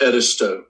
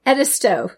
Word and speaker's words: Edisto: How do I pronounce that? – It's Edisto. – Edisto. It's a good thing I asked you.